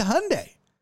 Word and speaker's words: Hyundai: Is it Hyundai? Hyundai? I'm like, it's Hyundai. Who says Hyundai? You know who says Hyundai? Old white women.